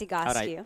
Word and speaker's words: Egoscue: First of all, people can't pronounce it correctly. It's Egoscue.